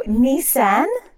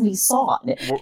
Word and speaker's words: Nissan: And also, Nissan? I have never heard it pronounced that way. I've always heard it as Nissan, fuckers.